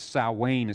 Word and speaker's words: Samhain: It looks like Samhain there, but it's Samhain is the way it's pronounced.